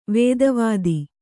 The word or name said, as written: ವೇದವೇತ್ತ